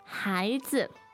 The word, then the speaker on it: 孩子: And 孩子, 孩子. – Okay, so the 孩 is second tone. – And the 子 is neutral tone.